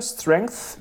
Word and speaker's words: Strength: Das Album heißt Strength, so wie Stärke, Strength. – Danke, dass du das für mich ausgesprochen hast. Ja. – Strength, TH, weißt du? TH.